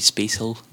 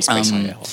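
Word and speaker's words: SpaceIL: It's maybe Space Hill, they might shout at me if I'm pronouncing that wrong. – It's got to be Space IL.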